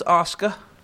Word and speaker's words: Asuka: Then we had Asuka versus Mickie James. She won in 13 minutes. – The name is pronounced Asuka. I just realized that it's actually Asuka.